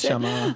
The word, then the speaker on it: neshama: When I'm looking through these things, I was very familiar with ruach, but not this other word, neshama, I'm not exactly sure how to pronounce neshama. – It. – Yeah. Usually I think that the emphasis is on the final syllable.